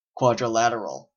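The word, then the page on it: quadrilateral: Canadian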